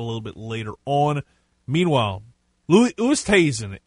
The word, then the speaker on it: Oosthuizen: It's Louis Oosthuizen.